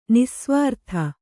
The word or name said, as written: ನಿಃಸ್ವಾರ್ಥ